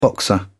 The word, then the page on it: boxer: British English